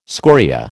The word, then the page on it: scoria: American English